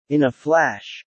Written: in a flash

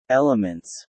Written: elements

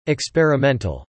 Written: experimental

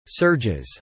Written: surges

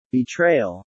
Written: betrayal

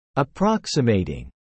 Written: approximating

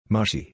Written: Mushy

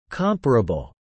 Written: comparable

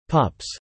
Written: pups

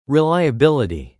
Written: reliability

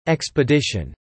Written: expedition

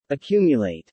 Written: accumulate

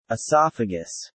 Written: Esophagus

